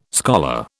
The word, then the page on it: scholar: British English